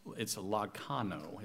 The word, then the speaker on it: lagchano: The obtained, and that actually that Greek word for obtained is lakano, is how it's pronounced. But lakano, L-A-G-C-H-N-O, A-N-O, actually, L-A-G-C-H-A-N-O.